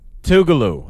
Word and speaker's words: Tougaloo: How do you pronounce that school again? – Tougaloo. I went to Tougaloo College in Jackson.